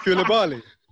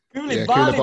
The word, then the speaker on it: Koulibaly: How do you say it? – Koulibaly. – Koulibaly, bro.